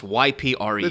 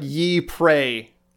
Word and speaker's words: Ypres: Yeah, it's Ypres. – It sounds... Mm-hmm. So it's pronounced like Ypres.